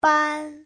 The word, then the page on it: 班: ban1